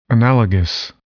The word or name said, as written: analogous